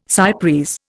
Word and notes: cy pres: Indian

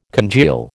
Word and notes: congeal: American English

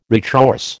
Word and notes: retrorse: American English

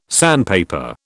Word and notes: sandpaper: British English

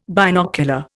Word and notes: binocular: Indian

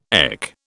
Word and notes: EC: British English

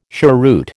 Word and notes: cheroot: American English

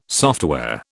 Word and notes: software: British English

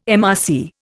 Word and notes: MRC: Indian

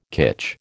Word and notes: kitsch: American English

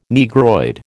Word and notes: Negroid: American English

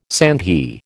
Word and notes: sandhi: American English

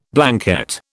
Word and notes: Blanquette: British English

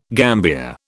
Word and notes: gambier: British English

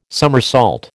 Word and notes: summersault: American English

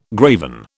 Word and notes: graven: British English

